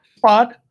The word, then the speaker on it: Bad: But we're in Germany, and I'm pretty sure you don't pronounce this the way I think you do. Is it Bad Homburg? – Bad. – Bad? – Yeah.